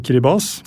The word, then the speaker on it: Kiribati: Ja, det heter jo ikke Kiribati. – Nei, selv om det mange som leser vil jo anta det selvfølgelig. – Ja, det heter Kiribati. T-I uttales vanligvis ikke S.